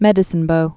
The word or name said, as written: Medicine Bow Mountains